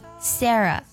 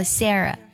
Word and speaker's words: Sarah: 另外呢，字母 H 在词尾的时候是不发音的，比如说呢，一个人的姓名 Sarah 拼作 S-A-R-A-H，最后呢以 H 结尾，那么这里呢 H 就不发音，这个人的名字读作 Sarah Sarah。